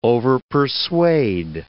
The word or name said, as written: overpersuade